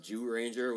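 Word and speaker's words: Zyuranger: We have, it's spelled Zyu Ranger, but it's pronounced Jew Ranger, which is the original Mighty Morphin Power Rangers, Dino Rangers story.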